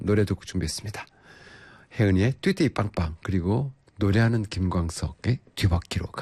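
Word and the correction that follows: 두 바퀴: 가는 뒤바퀴? 뒷바퀴 아니고 두바퀴죠? 두바퀴, 큰일 났다. 발음 안 된다. 다시 해볼게요. 김광석입니다. 두바퀴로 가는 자동차.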